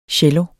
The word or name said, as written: CELLOEN